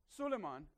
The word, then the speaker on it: Suleiman: Suleiman is the Arabic pronunciation of Solomon.